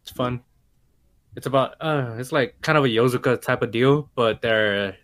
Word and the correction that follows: Yakuza: But the Yakuza? – Yakuza, damn fuck, son of a bitch.